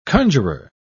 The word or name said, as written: conjurer